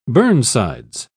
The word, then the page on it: burnsides: British English